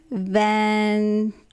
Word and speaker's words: Ventimiglia: Milo Vianetti or whatever? – Yes. – Well, how do you say that? Was that? – Ventimiglia.